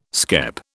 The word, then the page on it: skep: British English